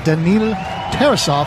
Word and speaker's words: Daniil Tarasov: Their backup goalie, Daniil Tarasov, if I'm pronouncing that correctly. – Yes, very on D, we have number 77, Mikko Mikkola.